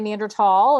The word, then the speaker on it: Neanderthal: That's not my choice, but the formal name, the Latin name given, it was Thal, but now because of the modernization of German, right, we don't pronounce that. So some of us say Neanderthal and some people say Thal, but it's, you know, we try not to shame each other for using either convention.